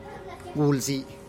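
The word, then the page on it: gul'zi: Teotitlán del Valle Zapotec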